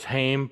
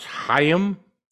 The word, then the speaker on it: Haim: which I always thought was Haim, but apparently it's pronounced Haim.